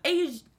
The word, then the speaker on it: Aja: Oh, Asia. Yeah. No, it's okay. – Yeah, Asia. – It's spelled AJA. Oh, is it spelled like Asia? – Asia.